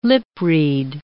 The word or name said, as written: lip-read